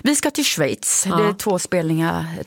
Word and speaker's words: Schweiz: Och du säger det helt perfekt. Schweiz, det är inte helt lätt. – Ja. Ja. Ja. – Ett ord med många uttal.